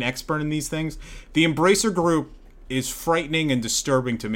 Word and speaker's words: Embracer Group: The Embracer Group. Say it like that. Embracer Group.